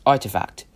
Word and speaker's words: RTFKT: One of Galaxy Interactive's investments was in RTFKT, pronounced Artifact, which specializes in digital streetwear, typically one-of-a-kind digital sneaker designs in the metaverse.